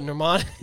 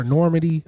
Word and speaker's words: Normani: Oh, Normani. – Normani. I want to call her Normandy. I want to call her everything but her name.